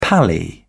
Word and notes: pally: British English

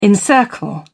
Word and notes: encircle: British English

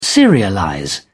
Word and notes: serialize: British English

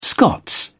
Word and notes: scots: British English

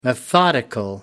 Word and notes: methodical: American English

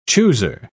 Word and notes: chooser: British English

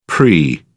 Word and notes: pre: British English

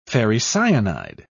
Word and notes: ferricyanide: British English

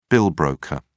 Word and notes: bill broker: British English

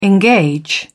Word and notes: engage: British English

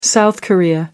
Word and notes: South Korea: American English

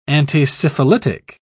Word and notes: antisyphilitic: British English

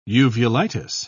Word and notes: uvulitis: British English

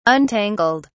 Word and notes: untangled: British English

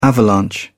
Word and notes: avalanche: British English

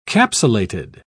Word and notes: capsulated: British English

